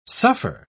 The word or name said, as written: suffer